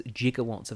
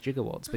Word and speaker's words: gigawatt: this is an obscure but once standard pronunciation of gigawatt, which is a billion watts. It's usually pronounced with the hard G as in gander and gold.